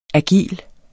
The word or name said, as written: agil